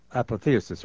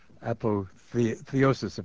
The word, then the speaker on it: apotheosis: But it is an apotheosis, or apotheosis, if you pronounce it that way, of Lincoln.